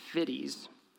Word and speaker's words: Fiddes: And it's written by several different authors, but this one section I'm going to be quoting is from a guy named Dr. Paul Fiddes. I looked up how to pronounce that, so I'm pretty sure that's right.